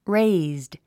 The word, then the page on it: raised: réizd; レェイズド